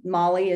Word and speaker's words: Molly: Molly is spelled M-O-L-L-Y.